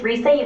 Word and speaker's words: Risa: Okay, my name is Risa Yuki. It's spelled R-I-S-A. Last name is Yuki, Y-U-K-I.